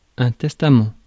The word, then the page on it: testament: [tɛs.ta.mɑ̃]